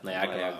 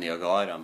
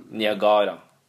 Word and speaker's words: Niagara: Niagara. – Ja, jeg tror det. – Jeg tror det er en uh, Vi sier Niagara, men Niagara.